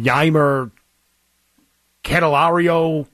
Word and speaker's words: Yimer Candelario: On a 104-mile-per-hour ground ball that collided painfully with Tiger third baseman, I don't even know how to say his name, Yimer Candelario.